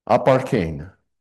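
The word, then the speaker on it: aparchein (ἀπαρχήν): And that word is aparchein. A-P-A-R-C-H, long E-N. Aparchein.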